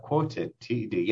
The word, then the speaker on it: QWOTD: Is it only for finance, the QWOTD? Did I say that right? – Quoted TD, yeah.